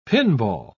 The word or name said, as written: pinball